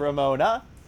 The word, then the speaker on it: Ramona: Ramona. It's not Ramono. It is Ramona.